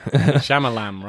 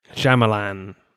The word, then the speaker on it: Shyamalan: Night Shyamalan, or however you say his name. – Shyamalan, right? – Shyamalan.